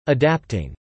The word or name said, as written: adapting